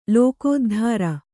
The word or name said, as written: ಲೋಕೋದ್ಧಾರ